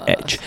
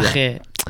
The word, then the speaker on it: Edge: Age. No, estamos hablando de Edge.